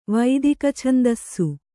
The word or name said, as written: ವೈದಿಕಛಂದಸ್ಸು